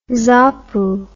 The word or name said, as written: zapu